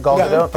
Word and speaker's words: Gal Gadot: Am I saying that right? Gal Gadot? – Yeah, okay, good.